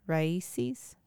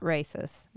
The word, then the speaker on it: RAICES: But we were working together on Twitter, via Twitter, our respective Twitter followers, to help raise money for, I always say it wrong, Raices. – Mm-hmm. – It's not racist, is what it looks like.